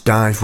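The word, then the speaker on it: DIIV: For Ian, a song for Guy, here's Dive, spelt incorrectly. Ian in Highbury recommended that. That's D-I-I-V pronounced dive with How Long Have You Known.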